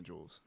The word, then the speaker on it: Juelz: Julian, but everybody calls me Jules.